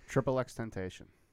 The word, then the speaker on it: XXXTentacion: And the joke was mentioned this rapper whose name I'm going to not pronounce correctly. – XXXTentacion. – XXXTentacion? – Yeah. – Okay, cool. I don't know why I was saying like XXXTentacion or something.